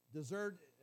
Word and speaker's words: dessert: Just dessert is spelled like D-I-H-Z-U-R-T. That's how it's pronounced. I'm saying this incorrectly. Dessert is pronounced as dessert, okay, D-I-H-Z-U-R-T.